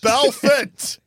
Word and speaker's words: Belfort: Ford or Fort? – Belford. – Belfort? It did sound like a D.